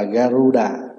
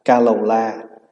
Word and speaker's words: Garuda: Tiếng Phạn đọc là Garuda, mà mình có nhiều cách phát âm. Một là mình đọc là Kalola, có khi mình âm là Yết Lộ Trà.